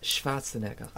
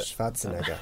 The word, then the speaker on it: Schwarzenegger: It's pronounced Schwarzenegger. – Ah. – Is it? – Schwarzenegger.